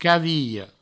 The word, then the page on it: cavilha: provençal; caviho